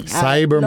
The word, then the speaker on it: Cyber Monday: Cyber Monday.